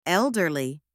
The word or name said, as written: elderly